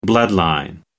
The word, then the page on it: bloodline: American English